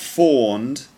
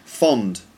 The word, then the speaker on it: fond: It's not fawned, but fond. Fond. Yeah, I'm really fond of that picture. Yeah, not fawned.